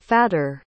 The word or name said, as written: fatter